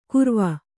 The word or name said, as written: ಕುರವ